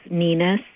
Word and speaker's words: Nina: My website is Nina C. Payne, and my last name is spelled P-A-Y-N-E, first name N-I-N-A, and .com.